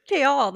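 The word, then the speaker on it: Chaol: Kaol, that's how you say it. – And that's so weird to me. – That's how you're supposed to say it.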